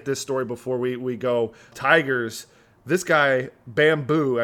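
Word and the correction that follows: Akil Baddoo: I think that's how you say it. Let me say his name right. You know who I'm talking about? – Akil Badu? – Badu.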